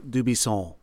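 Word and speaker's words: Dubuisson: And in his home country, his name is pronounced Dubisson, right? With a Gallic N that all sound in your nose. Mm-hmm. But in the United States, we either say his name is Dubisson or Dubisson, hard N.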